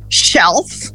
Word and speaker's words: shelf: Então, shelf. Tá vendo? O L, de novo, não tem som de U.